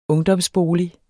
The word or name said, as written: ungdomsbolig